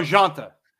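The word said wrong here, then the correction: Jhonata: Or no, it's Janata. This one's Janata. – Yes, it's Janata. That's right. There's an A there.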